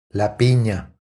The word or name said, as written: La pina